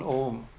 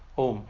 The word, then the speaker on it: Om: There's a way of pronouncing Om. Right, where the goes, it doesn't end. It's not. It doesn't end. It trails.